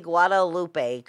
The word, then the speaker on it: Guadalupe: G U A D A L U P E.